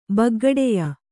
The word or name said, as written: ಬಗ್ಗಡೆಯ